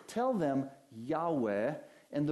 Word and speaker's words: Yahweh: And when we put vowels between those consonants, it's often pronounced as Yahweh, or sometimes people have pronounced it as Jehovah.